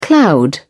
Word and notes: cloud over: British English

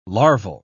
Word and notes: larval: British English

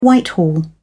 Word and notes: Whitehall: American English

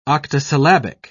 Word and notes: octosyllabic: British English

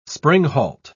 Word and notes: springhalt: British English

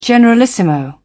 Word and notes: generalissimo: British English

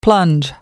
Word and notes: plunge: American English

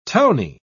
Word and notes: towny: British English

